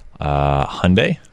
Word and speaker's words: Hyundai: And then another car company, H-Y-U-N-D-A-I. – Hyundai.